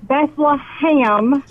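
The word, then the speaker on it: Bethlehem: It's Beth-LUH-HAM. – Yes! Yes! – It is not Beth-LUH-HAM. There's in no way is there. – I told you! – But it is pronounced by locals as ham.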